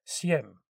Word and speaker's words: Sejm: And then France put pressure on the Primate of Poland to call a convocation of the Sejm, which is spelt S-E-J-M, but pronounced Sejm or Sejm, I think, and pass a resolution forbidding the candidacy of foreigners, which effectively barred young Augustus and Manuel of Portugal from the race.